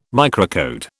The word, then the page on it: microcode: British English